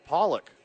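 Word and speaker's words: Pollack: You pronounce it Pollock. – Yeah.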